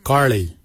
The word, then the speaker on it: gilyi: Darb is spelled D-H-A-A-B. It is a traditional knife, a darb. For boomerang we say garli. Garli is the traditional name for boomerang. Garli is spelled G-I-L-Y-I, garli.